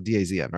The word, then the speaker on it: DAZN: You pronounce it though, DAZN, right? – Yeah, no, you're right.